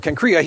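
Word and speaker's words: Cenchreae: At uh, uh, uh, uh can, I looked up how to pronounce this word, I forgot already. Uh, can, can, can Cancrea, there we go, Cancrea, he had cut his hair, for he was under a vow.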